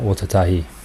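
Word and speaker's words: Ōtautahi: I always say this wrong. Otatahi. No, I got that right.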